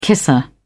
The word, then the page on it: kisser: British English